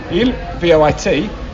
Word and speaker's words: voit: And the il and the je, if the je is je vois, V-O-I-S, and il, V-O-I-T, but given you don't pronounce the S or the T, je vois, il voit, they sound the same.